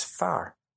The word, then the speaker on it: fear: It hit me like a ton of bricks when I realized that the Irish word for man is far. But it's spelt F-E-A-R.